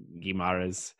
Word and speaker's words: Guimarães: Speaking of Newcastle, they made another big splash, signing Bruno Guimarães. I don't know if I'm pronouncing that right, from Lyon. – Guimarães.